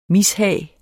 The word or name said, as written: mishag